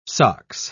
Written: sox

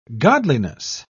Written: godliness

